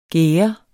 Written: gære

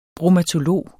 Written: bromatolog

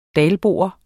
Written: dalbo

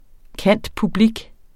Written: cand.public.

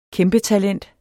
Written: kæmpetalent

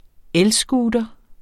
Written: elscooter

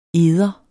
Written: eder

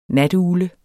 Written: natugle

